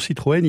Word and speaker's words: Citroën: Ben oui, parce que comme on l'a dit tout à l'heure, Franck, sur Citroën, il n'y avait pas de E tréma, ce qui était difficile à prononcer. Donc effectivement, au lycée Condorcet, il a mis opportunément un tréma sur le E, puisque ça lui a donné ensuite une consonance beaucoup plus aisée.